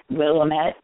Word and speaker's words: Willamette: Is it Willamette? – Willamette, damn it. – I guess I didn't have an accent on the right.